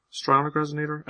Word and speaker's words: Strionic Resonator: Stryonic Resonator? Is that how you pronounce it? – Sounds about right. – Strionic Resonator. Sounds good.